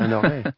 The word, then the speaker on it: Honoré: I'm here, I'm delighted to say, with the rather fabulous Karl Honoré. Have I ever said that right? Honoré. – Hang on, yeah. – Honoré. And that's, it's kind of Canadian twang there, right?